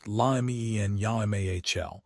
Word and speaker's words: Lamine Yamal: If you try to get too cute by picking undervalued players like Lamine Yamal, pronounced Lamien Yamahl, you might see your scores drop.